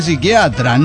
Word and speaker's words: psiquiatra: No, se dice psiquiatra, no psiquiatra. La P no se pronuncia, señor.